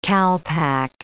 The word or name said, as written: calpack